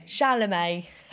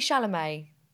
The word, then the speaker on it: Chalamet: Calumet. – Chalamet. – Okay. Fucking hell. Take two. Timothy Chalamet.